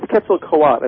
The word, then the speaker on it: Quetzalcoatl: Is it Quetzalcoatl? – I think it's Quetzalcoatl. I don't think you pronounced the last L.